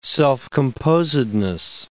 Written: self-composedness

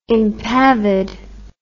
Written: impavid

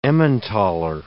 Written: Emmenthaler

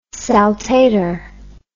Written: saltator

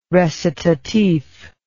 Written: recitatif

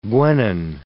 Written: guenon

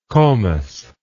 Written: comus

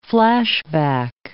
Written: flashback

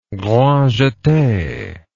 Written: grand jete

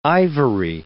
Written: ivory

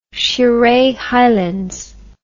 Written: shire highlands

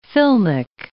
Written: filmic

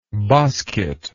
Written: bosket

